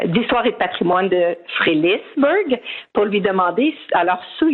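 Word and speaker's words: Frelighsburg: Mais je dois vous dire que moi-même, quand, ben, en fait, même ici, dans la communauté, on ne s'entend pas sur comment le prononcer. Alors, il y a le Frelichberg, oui. – Frelichberg, oui. Et beaucoup disent Frély, tout simplement.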